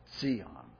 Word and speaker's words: Zion: and which is known by the name Zion, or Zion, as the Hebrews would have pronounced it, Zion.